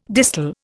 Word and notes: distal: Indian

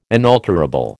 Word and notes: inalterable: American English

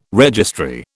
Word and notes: registry: British English

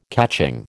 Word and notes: catching: American English

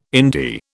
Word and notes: indie: British English

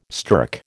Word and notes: stirk: American English

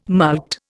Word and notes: mulct: Indian

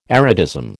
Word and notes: erotism: American English

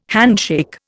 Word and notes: handshake: Indian

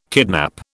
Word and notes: kidnap: British English

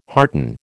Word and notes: hearten: American English

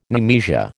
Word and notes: nemesia: American English

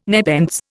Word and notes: nepenthes: Indian

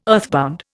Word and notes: earthbound: Indian

